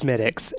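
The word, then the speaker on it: Smithwick's: He was like, it's Smithix. And I never forgot. – I knew not to pronounce the W, okay, but I guess good.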